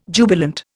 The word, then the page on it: jubilant: Indian